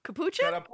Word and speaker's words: capuchin: Capuchin? – Carapuchin. Carapuchin? Capuchin. Capuchin. I can't pronounce. Capuchin. – I think it's capuchin.